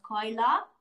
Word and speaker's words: Cayla: the way you actually say my name is Koila and for some reason, where the A is, they put an O in America.